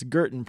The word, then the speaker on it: Gerton: Said it's Gerton, pronounced like curtain. – Nice. – So that's been running through my head all week. Gerton, like curtain.